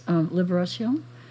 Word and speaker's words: Libération: Libération. Is that how you pronounce it? Mm-hmm. Liberation, basically.